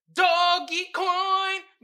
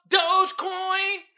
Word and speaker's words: Dogecoin: Doggy Coin, but it's pronounced Dogecoin.